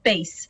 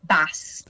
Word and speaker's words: bass: But just on that note, I just wanted to look up exactly why it is that it's spelt bass. We say it as bass when it's sea bass, but exactly. We say it as bass when we're talking about music.